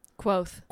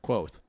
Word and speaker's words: Kvothe: I've settled on Quoth. – Quoth. – Quoth. It's spelled Kvothe. – Both. – But it's written, spelt. It's written, pronounced similar to Quothe.